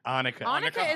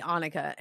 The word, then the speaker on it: Annika: Annika is the real pronunciation, yes. Believe it or not. But not, it was like originally Annika. And then ever since I was little, people call me Anika.